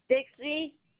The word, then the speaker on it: Dixie: Dixie. – Spell it for me. D-I-X.